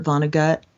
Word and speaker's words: Vonnegut: For instance, she has a friend who has a very depressed look at the world, and that friend loves Kurt Vonnegut, and that's how you say his name,